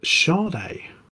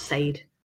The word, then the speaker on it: Sade: Very weird because it's spelled S-A-D-E. I just don't understand how they've decided that that's how it's going to be pronounced. – Well, that's how I pronounce it. I'll be wrong all these years. – But where are you getting the sh from? – I'm sure that's how it's pronounced.